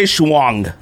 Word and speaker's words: Shuang: You still said it wrong. – Dang, what is it? 3 years later, you're still saying it wrong. – What's your last name? – It's Shuang. Like... – Jay Shuang, okay. – There you go. – Shuang at Shanghai Souls to most of y'all that know him. – If you say Shuanghai from now on, you can't ever mispronounce it.